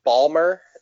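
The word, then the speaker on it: Baltimore: Actually, we call it Merland down here. We don't pronounce all the letters. Yeah, Merland and Balmer is the hometown Balmer.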